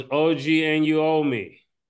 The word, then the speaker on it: Anunoby: No, it's OG Anunoby.